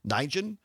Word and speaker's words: Nguyen: So Kenzie, and I apologize, I'm probably going to butcher her last name pronunciation, is N-G-U-Y-E-N, Nijen.